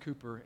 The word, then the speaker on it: Cowper: His name is spelled Cowper, C-O-W-P-E-R, but it's pronounced Cooper,